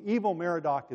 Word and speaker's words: Evil Merodach: His son, Amel Marduk, or Evil Merodach, as it's written in Kings, becomes king. Evil Merodach is closer to the way it was actually pronounced.